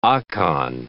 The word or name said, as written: Akan